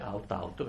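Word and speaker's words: aut aut: Dovrei avervelo già detto, vi ricordo che out-out si scrive out-out perché è latino, non è inglese, quindi non è out ma out, proprio tale e quale.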